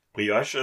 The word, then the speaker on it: Brioche: Brioche ist so ein bisschen süßer. – Ja, aber wie spricht man den Scheiß aus? – Brioche. – Broich. – Brioche ist französisch.